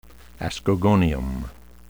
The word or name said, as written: ascogonium